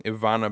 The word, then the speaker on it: Ivana: Anyway, though, Ivanka Bakiro was Marnie. Did I get her name right? – Ivana Bakiro. – Hey! – You said Ivanka, so...